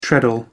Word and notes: treadle: American English

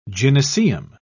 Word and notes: gynaeceum: British English

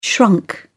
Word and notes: shrunk: British English

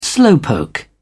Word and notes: slowpoke: British English

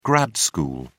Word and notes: grad school: British English

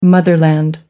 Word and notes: motherland: American English

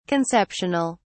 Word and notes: conceptional: British English

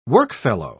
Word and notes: workfellow: British English

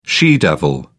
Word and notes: she devil: British English

